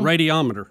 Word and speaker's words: radiometer: Radiometer.